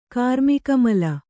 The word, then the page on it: ಕಾರ್ಮಿಕಮಲ: Kannada